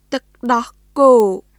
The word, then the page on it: ទឹកដោះគោ: トゥック・ドホ・コー; tɯk dɔh kòː